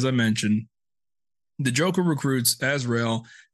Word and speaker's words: Azrael: How do you say Azrael? It's Azrael as it gets.